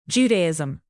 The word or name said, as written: judaism